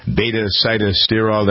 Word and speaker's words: beta-sitosterol: One of the things that PT9 has is great ingredients, salt, palmetto, pumpkin seed oil, beta-sitosterol.